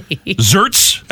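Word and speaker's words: Xertz: Zerts. That's how you say it, with a letter starts with Z, like xylophone. Zerts, right? I'm sorry, X. X-E-R-T-Z.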